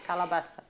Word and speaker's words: Calabasas: Calabaça. calabaça com Z. Ah, calabaçá. – Calabaçá, com Z. No, é, na última sílaba.